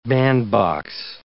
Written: bandbox

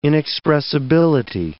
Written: inexpressibility

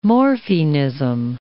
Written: morphinism

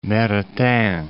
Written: Maritain

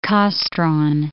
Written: Kastron

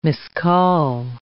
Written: miscall

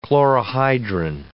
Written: chlorohydrin

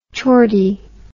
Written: chordy

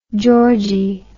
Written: georgie

